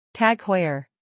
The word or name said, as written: TAG Heuer